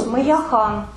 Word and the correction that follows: Мэя Хаян: Ой, Мэя Хаян, я забыла. – Мэя Хаян. – Мэя Хаян. Хаян. – Вот так произносится. Мэя Хаян. Да.